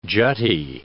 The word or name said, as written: jutty